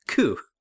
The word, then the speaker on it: coup: Coup. – A coup. Yeah, you're right. Yeah, it's spelled COUPS, but the P is silent.